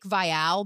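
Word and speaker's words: Viall: Well, his is Nick Vial, but I say Vial Files because I want it to rhyme with files.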